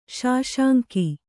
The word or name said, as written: ಸುಸಂಗ